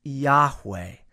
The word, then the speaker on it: Yahweh: His name, as we pronounce it, is Yahweh.